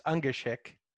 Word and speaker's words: Ungashick: Everybody trips up over it. It's Ungashick.